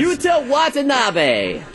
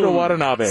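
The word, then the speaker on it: Yuta Watanabe: Utah Watanabe. – Oh, there you go. Boom. Utah Watanabe.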